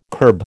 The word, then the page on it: curb: American English